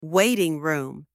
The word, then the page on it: waiting room: anglais